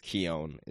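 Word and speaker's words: Keoghan: like this deleted scene is, and so they Barry like I think it's Keown is how it's pronounced. – You are correct.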